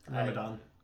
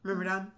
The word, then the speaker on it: Myrmidon: And I just found my favorite D&D creature, Air Elemental Myrmidon. Is that how you pronounce it? – Myrmidon. – Myrmidon, thank you.